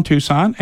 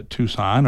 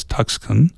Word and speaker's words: Tucson: And of course, many of the folks that have never been to Tucson are still pronouncing it as Tuxcan, and they understand, they think it's brown all the time.